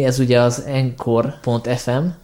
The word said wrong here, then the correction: anchor.fm: per filmvilág, tehát nem az encore, mint fájlmegosztó, hanem az anchor.fm.